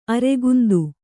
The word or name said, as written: ಅರೆಗುಂದು